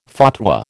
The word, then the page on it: fatwa: American English